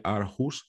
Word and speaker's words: Aarhus: se formó como investigador en la Universidad de Aarhus, Dinamarca. No sé si lo he pronunciado bien. – Bastante bien, bastante bien.